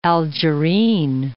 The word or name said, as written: Algerine